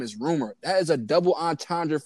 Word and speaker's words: RMR: So I'm doing some research and I don't know, people probably don't know who this is, but there's an artist named Rumor. He dropped a song called Rascal. – It's spelled RMR.